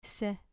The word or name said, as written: se